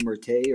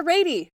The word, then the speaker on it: Ratey: That's the author of Spark, uh, Tim, however you say his name, Rattay or Ratty, Rattay, Ratty. Ratty. Yeah. Yes, Dr. Ra- no, Dr. Ratey. – Dr. Ratey, that's right.